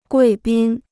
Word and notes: 贵宾: guì bīn

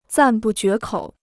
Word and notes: 赞不绝口: zàn bù jué kǒu

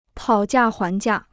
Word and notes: 讨价还价: tǎo jià huán jià

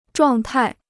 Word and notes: 状态: zhuàng tài

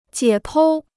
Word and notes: 解剖: jiě pōu

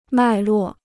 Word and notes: 脉络: mài luò

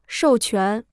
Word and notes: 授权: shòu quán

